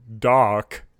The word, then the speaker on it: Daak: How would you pronounce the word D-A-A-K? – Well, I would pronounce it Dak, but Paul has corrected us. The pain from the comic strip is Dak, like Quark.